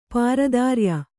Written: ಪಾರತ್ರಯ

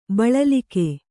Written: ಬಳಲ್ಕೆ

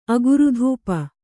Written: ಅಗುರುಧೂಪ

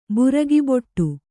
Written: ಬುರಗಿಬೊಟ್ಟು

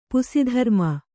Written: ಪುಸಿಧರ್ಮ

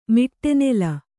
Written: ಮಿಟ್ಟೆನೆಲ

